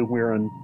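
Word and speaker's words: Wirrn: Yeah, it's actually Weirin, but yeah, there's no way to tell from that spelling.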